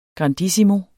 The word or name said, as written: grandissimo